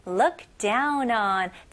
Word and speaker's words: look down on: 여기서는 look이 아니죠? 네, 으 발음에 더 가까워요. look, 그 다음에 다운이 아니고 이름절로 down, down. 그 다음에 on이 아니고요. 입술이 동그래지면 안 됩니다. 이건 한국식 발음이에요. 네, 영어는 on, 턱이 내려가는 느낌입니다. on, look down on.